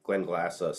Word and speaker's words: Glenglassaugh: Glenglassaugh.